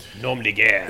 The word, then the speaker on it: nom de guerre: Nom de Guerre. – Nom de Guerre. Thank you very much. – You say it like you are the French. Say it right, French. Nom de Guerre.